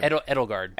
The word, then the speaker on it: Edelgard: Edelgard.